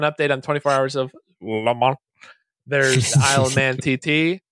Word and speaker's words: Le Mans: In France, it is said as Le Mans. Yes, okay. The S is silent here. Le Mans. – Lemon, dude.